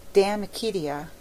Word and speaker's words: De Amicitia: We've finished Livy and De Senectut, and now engaged with De Amicitia, pronounced Damicitia.